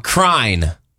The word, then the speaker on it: crine: Crine rhymes with fine. C-R-I-N-E.